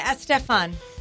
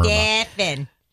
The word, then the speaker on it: Estefan: This is how I've heard it pronounce it. – Oh, sorry. – I've actually heard Estefan. – It from her. – Estefan. – Now, how do you say it? I asked her.